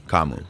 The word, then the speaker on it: Kamu: It's just, that's how it's pronounced. But Kamu. Yeah, it's just a long A. Kamu.